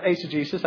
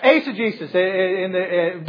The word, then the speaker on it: eisegesis: And I realize it can also be pronounced eisegesis, because Richard wants to say something to me about that. But yes, eisegesis. But it is to read into the text something that is not there.